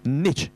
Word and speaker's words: niche: 都是輕音的咁但啊，唔係話咧重重地音嘅，應該係就係一個 niche 啊，都係輕音嚇。